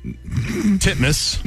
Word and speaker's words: Titmus: Pronounce it the way it's spelled. – Ariane Titmouse. Titmus. – There you go. – Is that how you say it? – Is it legitimately how you say it.